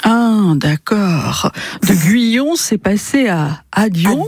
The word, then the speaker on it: Guyon: Et le nom d'origine, c'était Guyon. Alors, je le prononce comme ça, parce que ça s'écrivait au départ G-U-Y-O-N. Au Québec, en 7 générations, c'est devenu Guyon, oui. – G-U-I-O-N, et Guyon, Dion, voilà, à l'oreille.